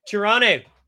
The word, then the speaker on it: Toronto: You didn't pronounce the second T. What did I say, Toronto? Toronto.